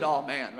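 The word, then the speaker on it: aman: It literally, I was listening to how you pronounce this, and it's like, oh man, But this is a good all man right here, okay?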